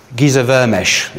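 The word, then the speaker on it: Géza Vermes: All this changed, by the way, in popular consciousness, particularly in biblical scholarship, with the writing of a book, this one, by a guy called Giza Vermesh, that's how you pronounce it.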